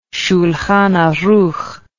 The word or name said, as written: Shulhan Arukh